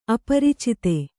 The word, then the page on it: ಅಪರಿಚಿತೆ: kannada; aparicite